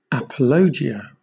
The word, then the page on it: apologia: British English